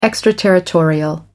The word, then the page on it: extraterritorial: American English